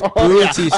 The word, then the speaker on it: Batista: Yeah, his name wrong, by the way. – Over under the amount of chance. What, Batista? – His name is Batista.